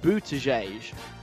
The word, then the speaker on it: Buttigieg: Pete Buttigieg, I believe is how you pronounce his name.